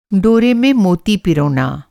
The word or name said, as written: पिरोना